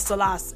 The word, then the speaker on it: Salas: People are thinking that Judge Salas, or Salas, I'm saying it correctly, that's the Spanish way of saying it.